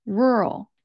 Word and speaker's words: rural: With my accent, the sound that I use is like from the word earl. An earl is someone who has a rank of nobility in the United Kingdom, and that word is spelled EARL, earl. And that's the sound that I use when I pronounce rural.